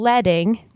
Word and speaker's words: leading: Like kerning, changes in leading, which is spelled L-E-A-D-I-N-G, but pronounced leading, alter the vertical distance between lines of text.